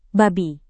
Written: babi

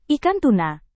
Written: ikan tuna

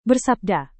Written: bersabda

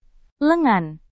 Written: lengan

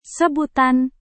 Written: sebutan

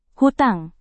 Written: hutang

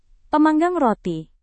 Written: pemanggang roti

